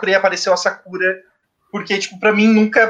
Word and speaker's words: Sakura: Não tem Sakura, era Sakura, se fosse para se é a pronúncia correta, porque as, é, o, a maioria é oxítona, ou seja, a tônica é a última sílaba, sakura, que no caso é como é a pronúncia corretamente em, em japonês, ou às vezes paroxítona, que é a, a penúltima sílaba.